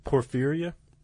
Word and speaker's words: Porphyria: Porphyria. Is that how you pronounce that? – Porphyria. – Porphyria? All right. We'll go with that.